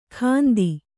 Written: ಗಂತಿ

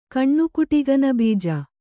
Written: ಕಣ್ಣುಕುಟಿಗನಬೀಜ

